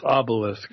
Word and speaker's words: obelisk: For those of you that didn't understand Rollins' word, it's obelisk. – Obelisk is the correct way to say it.